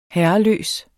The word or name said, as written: herreløs